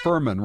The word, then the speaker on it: Furman: Furman rhymes with vermin.